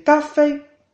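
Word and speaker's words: café (咖啡): Café en chino se dice café, café, café, con un primer tono en la a de ka y un primer tono en la e de fei, café,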